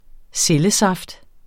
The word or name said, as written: cellesaft